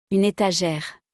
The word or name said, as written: une etagere